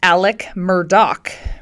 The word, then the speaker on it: Murdaugh: But let's start with, I know we talked about, I called him Alex Murdaugh. Now, I guess his name is pronounced Alec Murdaugh, right? So I mispronounced it, and I really totally didn't even scratch the surface of the weirdness with his family, nor the body count.